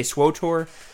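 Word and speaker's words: SWTOR: You say SWOTOR? – SWOTOR. – But it's not spelled that way. There's no extra O.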